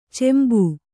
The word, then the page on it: ಚೆಂಬು: Kannada